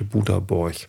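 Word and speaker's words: Boda Borg: Ähm, und dann gab es noch was drittes Tolles, was wir erlebt haben, was auch nochmal eine Sonderfolge werden könnte: Buda-Borch. Ich Auch schon mal verraten. Buda schreibt man B-O-D-A. Borch wie Björn Borg als ich das erste Mal Buderbräuch gehört habe, habe ich Puderbär verstanden und dachte, wieso fahren wir zu Puderbär?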